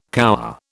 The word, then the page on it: cower: British English